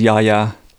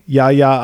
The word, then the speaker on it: Yahya: His name is Yahya. – Okay. Yahya Abdul-Mateen II.